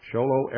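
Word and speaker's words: Show Low: Folks, sholo is spelled exactly like it sounds like it's spelled. It's two words, show and low. And yes, it's the same show and low that you learned in first grade.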